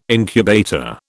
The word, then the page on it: incubator: British English